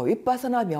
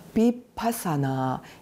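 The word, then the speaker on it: vipassanā: 그럼 지금부터 윗바사나 명상에 대해서 간단히 얘기를 해볼게요. 윗바사나, 비파사나 여러 가지로 발음을 할 수는 있는데 네, 꿰뚫어서 봄이라는 그런 어떤 말이에요.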